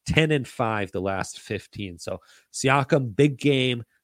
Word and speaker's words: Siakam: And then Siakam. I love how Shaq pronounces Siakam's name, Yakum, and over 3.5 assists.